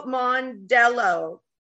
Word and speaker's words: Mondello: Jennifer Steele Mondello.